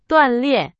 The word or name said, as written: 锻炼